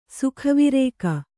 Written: ಸುಖವಿರೇಕ

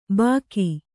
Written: ಬಾಕಿ

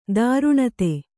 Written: ದಾರುಣತೆ

